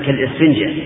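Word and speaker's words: كالإسفنجة: هذه الوصية لا تجعل قلبك كالسفنجة أنا أحسبها ألف أحسبها همزة كالسفنجة هكذا ننطق بها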